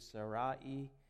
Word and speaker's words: Sarai: We've been talking about Abraham and Sarai, as we pronounce that here in America. If you want to know how you would pronounce that back then, it would be Sarai.